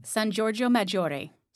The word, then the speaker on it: San Giorgio Maggiore: San Giorgio Maggiore.